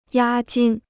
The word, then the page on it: 押金: yā jīn